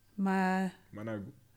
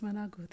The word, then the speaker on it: managu: Spinach or, I hope I say this right, managu. – Yes, managu.